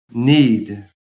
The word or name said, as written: NEED